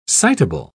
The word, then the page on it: citable: British English